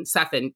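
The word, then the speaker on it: Cefinn: it's C-E-F-I-N-N, Seffin, thank you.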